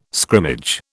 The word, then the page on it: scrimmage: British English